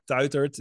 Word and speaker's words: Tuitert: So you can follow me on Instagram, on LinkedIn, just by my name, Mark Tuitert. It's hard to pronounce in English. You did it quite well, actually. T-U-I-T-E-R-T.